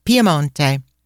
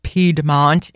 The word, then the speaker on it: Piemonte: Allora, we want to share with you why you should visit the region of Piemonte, which all of you know is Piedmont in English, but Piemonte, we're sticking to the Italian version.